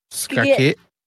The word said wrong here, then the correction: Skagit: The G is pronounced as a G, so it's Skagit.